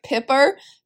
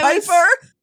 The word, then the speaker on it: Piper: We have gotten on her case so much for pronouncing things wrong, like Pipper, Pipper oh instead of gosh. Piper.